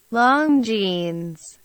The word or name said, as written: Longines